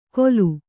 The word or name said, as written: ಗೋಲು